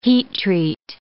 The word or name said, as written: heat-treat